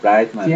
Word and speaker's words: Brightman: Brightman.